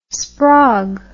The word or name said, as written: sprog